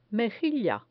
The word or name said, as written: mejilla